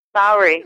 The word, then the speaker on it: Maori: First of all, we should ask you how to pronounce it. – Maori. – Maori? Okay.